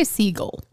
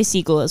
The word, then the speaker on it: Segel: I say Segel. – I say Segel as well. – Like the bird.